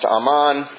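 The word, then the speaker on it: aman (אמן): At the first part, you can read these on your own, but the Hebrew verb amen is pronounced aman. See, I didn't even say it right.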